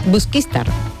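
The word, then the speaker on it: Busquístar: Busquistar. El acento. Me ha faltado el acento.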